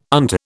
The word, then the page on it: unto: British English